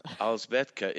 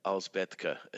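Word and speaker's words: Alžbětka: Alzbetka. It's a variant on Elizabeth. – Okay. – Like Elsbeth or, yeah, Alzbetka. – Alzbetka.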